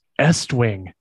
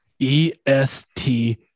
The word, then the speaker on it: Estwing: This person says, it's Estwing, Estwing, not Estwing.